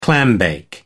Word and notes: clambake: American English

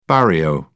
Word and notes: barrio: British English

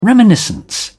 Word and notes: reminiscence: British English

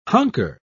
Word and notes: hunker: British English

